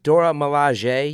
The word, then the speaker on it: Dora Milaje: And it's pronounced Dora Milaje, you guys.